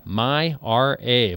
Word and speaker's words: MyRA: And at the 2014 State of the Union, he described the MyRA. Of course, that rhymes with IRA,